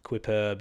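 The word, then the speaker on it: Quippurb: So it's Q-U-I-P-P-U-R-B, Quiperb. You can just think of it like superb quips.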